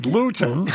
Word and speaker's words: lutein: You spelled it L-E-U-T-I-N when in fact, correct spelling is L-U-T-E-I-N. And you pronounced it lutein. – That's right. – When in fact, the correct pronunciation is, it says here, unbeknownst to me, lutein.